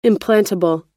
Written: implantable

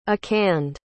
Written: akande

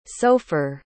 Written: sofer

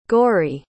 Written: gorry

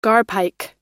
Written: garpike

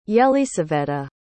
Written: yelysaveta

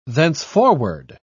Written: thenceforward

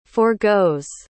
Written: forgoes

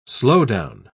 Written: slowdown

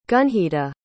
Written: gunjita

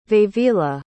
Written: vevila

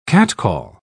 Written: catcall

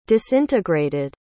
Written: disintegrated

